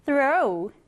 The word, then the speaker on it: throw: 첫 번째 단어는 throw, 던지다예요. 우리 보통 이렇게 th가 붙어 있으면 어떻게 발음을 하나요? th, 이렇게 발음합니다. th, 그래서 throw, 이렇게 발음을 해주시면 되는 거예요.